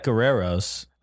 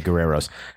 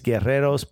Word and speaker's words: Guerreros: I get a lot of people going, "Oh, uh, Mr. Alexis uh, Guerrero," and I'm like, "No, no, no, no, no! Don't you? You will die as a white person if you try to roll your R. Just let it go.